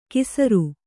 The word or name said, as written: ಕೀಸರು